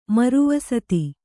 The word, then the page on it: ಮರುವಸತಿ: maru vasati